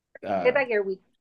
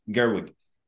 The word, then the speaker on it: Gerwig: Estas son las corporaciones donde sí estamos celebrando muchas ideas que Greta Work, se me olvidó el nombre, yo sé que es Greta, pero el apellido no sé pronunciarlo, la directora, ¿verdad? – Gerwig. – Gerwig.